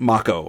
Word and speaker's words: Mako: So, it's Mako. I always thought it was Mako. Mako, I guess, makes more sense because it, Japanese pronunciation, it would be Mako.